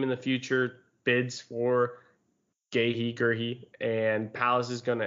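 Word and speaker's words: Guéhi: Mark Gahey or Gerhey is the correct pronunciation. – Gehe. I, have heard Gehi. – Gehi, Gerhi. I I've heard both.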